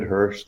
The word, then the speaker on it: Hirsch: And if that doesn't take you right to the podcast, you could type in my name, David Hirsch. The last name is spelled H-I-R-S-C-H.